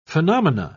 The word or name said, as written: phenomena